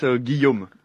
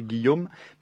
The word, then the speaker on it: Guillaume: Sería, tipo, es Guillaume, tipo, para pronunciar, tipo, un guión de cinema, pero om al final, así que Guillaume, pero no se escribe así, porque en francés no se escribe como se pronuncia, y así que Guillaume Sauvage.